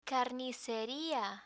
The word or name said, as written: carniceria